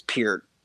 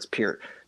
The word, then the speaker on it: Peart: Yeah, so the drummer for Rush, Neil Peart, which I just learned recently is actually how you say his name, is Peart. I know everybody calls him Pert, but it's Peart.